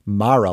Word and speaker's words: Mara: Importantly, to the specific discussion here, Rooney Mara does in fact break from the family and chooses to pronounce her name as Mara, like tiara, which also happens to be closer to the traditional pronunciation of that Irish surname.